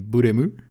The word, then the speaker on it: Blame: C'est un manga, donc euh, de toute façon, ça se prononce Buremu.